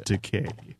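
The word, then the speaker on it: Tkay: It's pronounced Takei. Like, okay.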